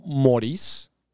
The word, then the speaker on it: Moris: Los usuarios que soliciten un préstamo y sean autorizados y utilicen el código MORIS, gente, si no saben cómo se escribe mi nombre, por favor, M-O-R-I-S, tendrán mil pesos de bonificación en su primera mensualidad.